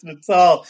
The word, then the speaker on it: Natal: I'm so glad I didn't have to say the title of your book because I've been pronouncing it natal. – Oh. Natal. – Yes, Natal. It's from the Portuguese, meaning Christmas or the birth of Jesus.